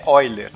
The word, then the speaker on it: Euler: Mas muitas vezes o ouvinte não sabe, vai ler num texto, vai ler Euler. É Euler, né? Assim, se a gente for pronunciar da pronúncia alemã.